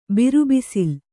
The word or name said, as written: ಬಿರುಬಿಸಿಲು